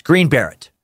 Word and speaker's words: Green Beret: Uh, I think it's actually pronounced Green Barrett. Uh, Joe Paisley told me today it's Green. It's Green Beret.